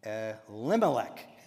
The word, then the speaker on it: Elimelech: No, Elimelech. He has four syllables, not three.